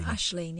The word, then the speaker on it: Aisling: You're saying ing. – Yeah, Aisling. A-I-S-L-I-N-G. Aisling Reynolds. – I wouldn't pronounce the G. – I'm not pronouncing the G. I'm pronouncing Aisling. If I was pronouncing the G, it would be Aisling. Like lamb.